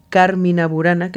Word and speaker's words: Carmina Burana: Es una obra compuesta por Karl Orff, que se llama Carmina Burana, mejor dicho, Carmina Burana, que sería la pronunciación más correcta.